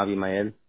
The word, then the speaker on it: Abimael: Yeah, it's spelled Abimael. – Abimael? – Yeah. – Okay, perfect. Is this supposed to be said in Spanish? Because I think in Spanish it's Abimael, right? – Yeah, Abimael.